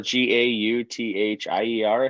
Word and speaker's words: Gauthier: His last name is, if you look at how it sounds, it's like Guthier. – Oh yeah, uh-huh. – G or something like G A U T H I E R.